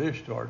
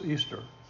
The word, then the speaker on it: Ishtar: Do you know how they pronounce Ishtar? I, I, I actually deliberately mispronounced her name, actually, because I never knew how to pronounce it. But her, now, her name is pronounced Easter. That's how you say her name.